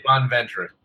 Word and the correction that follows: Bonaventure: Or Bonventure. I can't pronounce it. Whatever. – Bonaventure. – Bonaventure.